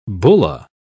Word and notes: bulla: British English

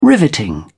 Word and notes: riveting: British English

